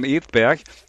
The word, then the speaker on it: Edberg: Stefan Edberg.